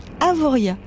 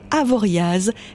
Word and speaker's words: Avoriaz: On dit Avoria et pas Avoriaz, car le Z final hérité du patois savoyard ne se prononce pas.